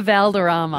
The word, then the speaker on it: Valderrama: Wilder Valderrama. – Wilma Valderrama.